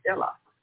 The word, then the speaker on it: Elah: And also Elah, where we get the word Allah, that's how we pronounce the Creator's name in Aramaic and Arabic. It's Elah.